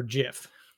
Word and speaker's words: Jif: And I call the peanut butter JIF. – Yeah, cause it's a J. – Because that's how it's spelled.